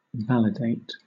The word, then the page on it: validate: British English